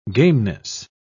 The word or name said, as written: gameness